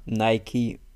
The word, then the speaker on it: Nike: Nike. Nike. Wird es offiziell ausgesprochen, aber sagt ja kein Deutscher. Adidas.